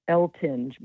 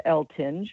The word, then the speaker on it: Eltinge: And the book again is The Dream Class by Patricia Eltinge.